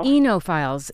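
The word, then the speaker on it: oenophiles: Enophiles.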